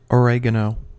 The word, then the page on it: oregano: American English